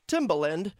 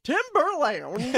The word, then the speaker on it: Timbaland: It's Timbaland. – Timbaland. – But man, when he read... – The first time. – He saw it on the prompter and it was Timberland. – I always pronounce his name like the shoes.